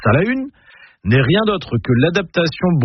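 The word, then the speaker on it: Salaün: Déjà, en breton, on prononce Salahune. Pas Salin, mais Salahune.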